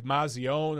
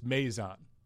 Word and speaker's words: Maison: A lot of people have been butchering his last name. It looks like Mazion or something like that. It's Maison.